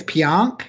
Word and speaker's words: Pionk: I think it's Pionk. Is that how you say it? Yeah. Yes. Correct.